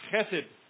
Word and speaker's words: chesed: chesed.